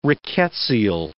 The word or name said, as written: rickettsial